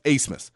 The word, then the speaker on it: Abmas: Spelled A-B-M-A-S, but it's pronounced Asemus.